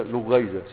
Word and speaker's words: لغيزة: فعيلة طيب بضم ففتح العين المشددة كلغيزة للغز، تقول ده لغيزة، سألقي عليك لغيزة يعني لغز، وخليطة للاختلاط، تقول وكان القوم في خليطة بمعنى في اختلاط.